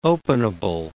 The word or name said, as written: openable